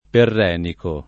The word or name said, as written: perrenico